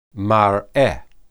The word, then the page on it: mareh: Hebrew